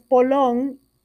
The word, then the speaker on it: polón: Esta palabra es polón, es una palabra aguda si se acentúa en la última sílaba debido a que termina en N.